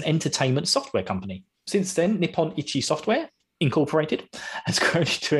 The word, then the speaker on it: Nippon Ichi: So, Disgaea is originally a game created by Nippon Ichi Software. I hope I'm saying that right. I keep saying like Nippalon. Nippon Ichi Software, which is also abbreviated to NIS to make it easier for people like me to speak it.